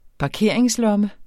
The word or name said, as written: parkeringslomme